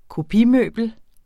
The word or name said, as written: kopimøbel